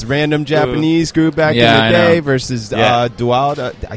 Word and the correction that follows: Doudna: Yeah, Jennifer Doudna.